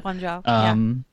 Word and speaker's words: Guangzhou: The last two were, I think it was Atlanta and Guangzhou, I think is how you pronounce that. – Guangzhou.